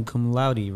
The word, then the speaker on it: cum laude: And graduating cum laude, right? Did mm-hmm. I say that right? – Oh, yeah, you got that.